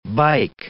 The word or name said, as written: bike